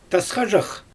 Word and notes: tasxadʒax: Xyzyl

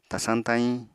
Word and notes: tasañ-tayiñ: Apatani

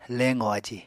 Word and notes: leqò aji: Apatani

